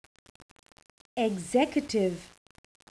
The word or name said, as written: executive